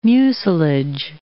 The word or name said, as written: mucilage